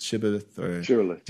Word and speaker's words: Shibboleth: Shibboleth? – Shibboleth. – Do you know what? – Shibboleth, yeah.